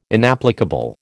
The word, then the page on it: inapplicable: American English